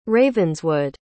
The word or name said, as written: ravenswood